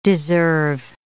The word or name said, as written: deserve